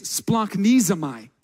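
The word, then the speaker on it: splanchnizomai: The Greek word right here used for felt compassion is splanknizomai. That's how you pronounce it, literally the hardest word in Greek.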